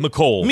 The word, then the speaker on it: Mecole: And I'm not saying I may butcher his first name. McCall Hartman? Right? – Mecole Hartman.